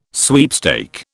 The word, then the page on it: sweepstake: British English